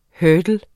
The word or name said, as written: hurdle